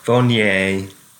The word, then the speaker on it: Viognier: I'm drinking a Viognier. Is that how you say it? – Viognier.